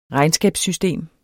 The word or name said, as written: regnskabssystem